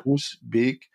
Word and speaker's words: Groesbeek: das heißt Groesbeek. Groes schreibt man damit G-R-O-E. – Ja. – Groesbeek.